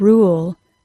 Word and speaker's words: rule: Now let's look at the word rule, R-U-L-E. That spelling is also a U consonant E, but it is not pronounced as a long U. The vowel sound in the word rule is the OO sound, OO, rule.